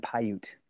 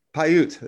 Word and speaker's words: Paiute: The northern Paiute. – Paiute, there you go.